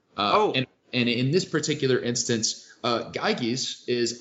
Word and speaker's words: Gyges: So in book two, he discusses an allegory about the Ring of Gyges. – Okay. – Which I hope I'm pronouncing that right. It's G-Y-G-E-S.